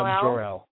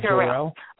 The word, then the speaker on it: Jor-El: And I'm just, I'm shaking my head because of all people, and I'm pretty sure he played the part, was Superman's dad, K.L., how do you pronounce it? – Jor-El. Yeah, Jor-El.